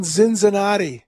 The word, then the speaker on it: Cincinnati: And I'm glad you're in Cincinnati now. It's great to have you and be able to. Relevant Radio. It's wonderful. – You're saying that wrong. It's pronounced Zinzinati.